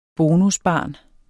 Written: bonusbarn

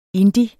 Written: indie

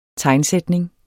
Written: tegnsætning

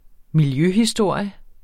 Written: miljøhistorie

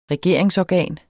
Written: regeringsorgan

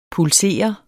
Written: pulsere

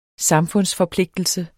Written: samfundsforpligtelse

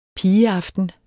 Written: pigeaften

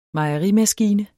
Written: mejerimaskine

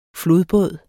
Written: flodbåd

